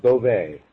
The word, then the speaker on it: Bove: It's spelled B-O-V-E. And Tony, how do you pronounce your last name? – Well, it's pronounced Beauvais.